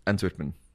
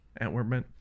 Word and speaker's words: Antwerpen: So, 7 years ago, you did your first hyperlapse in your hometown of Antwerp. Is that how you say it? – Antwerpen. – Antwerpen. – See how I roll that R there? – Yeah.